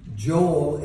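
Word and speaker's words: Joel (יואל): Today we're looking at Joel, or as it is pronounced in the Hebrew, Joel, El being the name of God.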